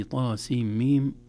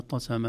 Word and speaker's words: طسم: في الواقع الحروف التي في أول السورة هذه طاسيم ميم إن لم تسمع وتتلقى صعب أن يقرأها الإنسان، أنها مختلفة في نطقها عن بقية الكلمات. بقية الكلمات تنطقها بحركاتها، هذه لو يعني لم نسمعها سنقرأها طسم أو طسم أو طسم أو كذا، إذا لابد أن نسمعها أو لابد أن تتلقى.